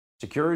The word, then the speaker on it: Sekur: That's why you need a new privacy and cybersecurity application tool called Secure. It's spelled S-E-K-U-R. Secure is using proprietary encryption and offering secure instant messaging and email.